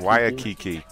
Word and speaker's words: Waikiki: Job, become a Giovanni Pastrami delivery driver in Waikiki. Did I say that right? – Waikiki.